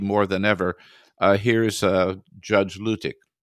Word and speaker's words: Luttig: By the way, I thought it was Luttig too, but they were all pronouncing it Luttig, so that's, I guess, what it is.